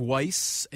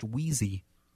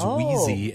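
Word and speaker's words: Wiese: A lot of Weiss, and actually it was so bad down – my grandpa grew up in Le Sueur, Minnesota, and down there it's actually pronounced Wheezy. – Wheezy? – It is Wheezy, and he changed the pronunciation when he came up up to the cities.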